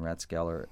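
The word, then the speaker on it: Rathskeller: Ratskeller. – We did with the hard T, The German way. Wow, you guys are really good on the pronunciation here. Very authentic, McElwain and Ratskeller.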